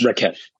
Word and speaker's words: Rakesh: Rakesh. Rakesh.